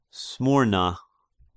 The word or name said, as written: Smyrna